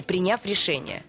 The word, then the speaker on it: приняв: Но я бы на «я» вот поставила ударение. Приняв решение.